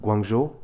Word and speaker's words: Guangzhou: Guangzhou.